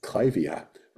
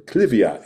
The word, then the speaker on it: Clivia: And the other one that you mentioned that I think is worth a little discussion is Clivia, or Clivia, as my British customers pronounce it.